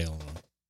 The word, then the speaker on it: Illinois: I think it's Illinois. – Yeah. – I think the S is silent. – I say Illinois.